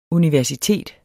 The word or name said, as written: universitet